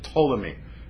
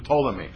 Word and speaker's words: Ptolemy: His name is King Ptolemy. Has one of those silent P's at the beginning of his name. King Ptolemy.